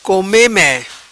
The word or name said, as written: Come me